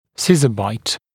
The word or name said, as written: scissor bite